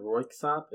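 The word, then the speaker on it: Røyksopp: Royksopp.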